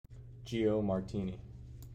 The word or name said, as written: Gio Martini